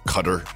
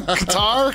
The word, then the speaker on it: Qatar: I think it's Cutter. – It's not. – Isn't it Cutter? Look it up. – Qatar is Q-U. – No, it's Cutter. It's not Q-U. – It's Q-A-T-A-R. – Yeah. – It's Qatar. – It's Cutter. – It's Qatar. – So some producer find the pronunciation of this country that we are going to. – Doha. Qatar. – Cutter. It's actually Cutter.